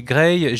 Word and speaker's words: Gray: Faut dire que ça se prononce de la même façon. C'est Grey, G-R-A-Y, hein,